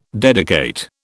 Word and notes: dedicate: British English

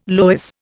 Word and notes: loess: Indian

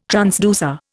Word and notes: transducer: Indian

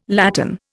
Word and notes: latten: Indian

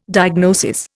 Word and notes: diagnosis: Indian

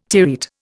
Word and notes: terete: Indian

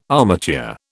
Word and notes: armature: British English

